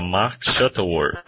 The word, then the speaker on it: Mark Shuttleworth: Mark Shuttleworth? – Bom, acredito que seja Mark Shuttleworth.